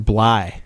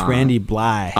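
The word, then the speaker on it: Blythe: Listening back to some of those older episodes of the Jamie Josta podcast, He was saying that Randy's name, last name, is actually pronounced Bly. – Ah. – It's Randy Bly.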